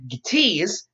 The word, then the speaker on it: Gaetz: And Matt Gaetz, that's how it's pronounced, there's a Z, has introduced new legislation to do the same thing very recently.